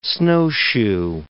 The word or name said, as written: snowshoe